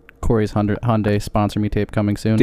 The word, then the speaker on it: Hyundai: Like they said that like when we first started training on Hyundai, they're like Hyundai Sunday. Say it right, because if you say it wrong on like the phone, people are gonna get pissed.